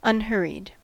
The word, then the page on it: unhurried: American English